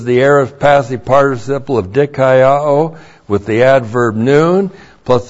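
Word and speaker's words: dikaiō (δικαιόω): The adverb noun means now, and then we, our good friend in Romans, the verb dikaiō. D-I-K-A-I-O long O. Dikaiō.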